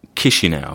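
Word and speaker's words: Chișinău: How is it pronounced? Chisinau? – Chisinau, apparently.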